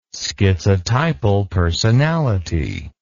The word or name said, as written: schizotypal personality